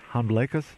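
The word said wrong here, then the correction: Hanbleceya: Hamblecha. – Hamblecha.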